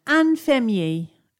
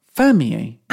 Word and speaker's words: infirmier: Numéro un, infirmier. – Fireman. – No. Listen to it, infirmier. – Fermier? – Infirmier.